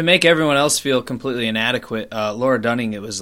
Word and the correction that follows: Lena Dunham: Lena Dunham? – Lena.